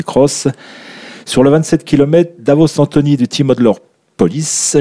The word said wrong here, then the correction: Outdoor Police: Je l'ai mal prononcé, Outdoor Police.